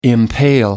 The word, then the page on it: impale: American English